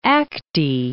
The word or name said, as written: Acte